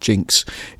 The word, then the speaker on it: DJNX: It's spelt DJNX, but I'm pronouncing it Jinx.